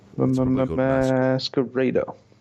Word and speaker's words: Masquerada: Anyway, I don't know if this game's pronounced Masquerada or Masquerader. – Masquerader.